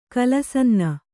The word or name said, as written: ಕಲಸನ್ನ